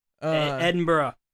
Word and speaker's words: Edinburgh: Edinburgh. That's how you say it. I'm not even kidding. – Edinburgh? – Edinburgh. – That says Edinburgh. – I know, but that's not how it's pronounced.